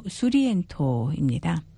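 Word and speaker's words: Surriento: 제목의 소렌토는 이탈리아식 발음으로 수리엔토입니다.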